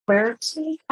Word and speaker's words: quercetin: The other thing I've discovered is quercetin. How do you say it? – Quercetin. – Quercetin.